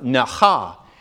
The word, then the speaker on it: naha: Instead of the ta'ah, it's naha. Starts with an N, not with a T, and it's got a ch in the middle of it. So it's pronounced naha.